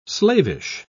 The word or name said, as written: slavish